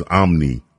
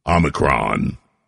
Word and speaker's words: Omicron: I keep mispronouncing it, I know. I just can't get it out of my mind. It's Omni. I know there's no Omni. Omicron.